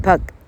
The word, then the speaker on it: pug: 这个个这个音哈，那一般的台客并没办法发，好，真的要发它是 pug 哈啊，其实这个字念作 pug 啊，